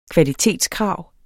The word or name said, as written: kvalitetskrav